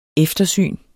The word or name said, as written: eftersyn